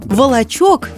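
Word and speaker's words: Вышний Волочёк: Название, а самое интересное – почему Волочок пишется через «ё»? Для меня это было реально откровением. Я привыкла – значок пишется через «о», Волочок через «ё».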